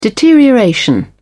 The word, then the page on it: deterioration: British English